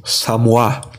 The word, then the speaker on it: Samois: S-A-M-O-I-S. Samois, que se lê em português, mas que se você for ler pelo original da palavra, que é essa palavra em francês, pronuncia-se Samoa. Então eu vou falar tanto Samois, que é o jeito que a gente conhece aqui no Brasil, como Samoa, beleza?